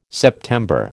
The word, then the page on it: September: American English